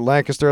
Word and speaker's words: Lancaster: That's right, I pronounce it Lancaster. That's the way it's supposed to be pronounced when you're from PA.